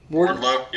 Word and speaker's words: Wardlow: How do you say his name? Wardlow? – Wardlow. Yeah, I'm pretty sure it's just Wardlow.